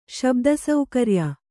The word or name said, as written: ಶಬ್ದಸೌಕರ್ಯ